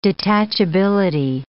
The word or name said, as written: detachability